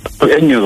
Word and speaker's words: Engin: Engin reicht, Engin Kilic, aber Engin reicht vollkommen.